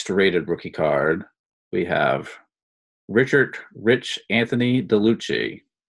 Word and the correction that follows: DeLucia: Did I say that right? – DeLucci. I would say DeLucia, I guess.